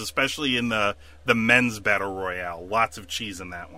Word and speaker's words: royale: I saw those battle royales, whatever. – Yes, that is actually the proper pronunciation. A royale.